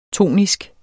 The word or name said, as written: tonisk